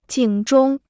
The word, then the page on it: 警钟: jǐng zhōng